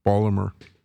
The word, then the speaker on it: Baltimore: that's how it's pronounced by people that come from that part of the world, Baltimore.